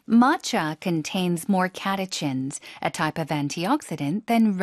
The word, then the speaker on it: catechins: Catechins라고 읽습니다. 영어에서는요. 발음 다시 한 번요. – Catechins. – 앞에 힘주고 catechins.